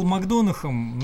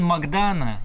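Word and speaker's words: McDonagh: Ну, ну знаете, он раньше был Макдонахом, нормально себя чувствовал в этом, в этом качестве. Потом сейчас стал Макдона. А вообще он Макдана, если уж так-то уж правильно ну, произносить. Да.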